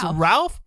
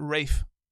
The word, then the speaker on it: Ralph: It's Ralph, but he pronounces it Rafe.